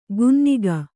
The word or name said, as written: ಗುನ್ನಿಗ